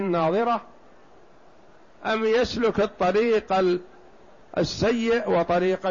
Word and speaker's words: ناظرة: التي النضارة والجمال والبهاء والحسن، وهي تنطق بالضاد، والأخرى تنطق بالضاء التي من النظر. نعم. – أي حسنة بهية مشرقة مسرورة إلى ربها ناظرة، أي تراه عيانا، كما رواه البخاري رحمه الله تعالى في صحيحه: إنكم سترون ربكم عيانا.